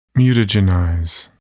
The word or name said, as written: mutagenize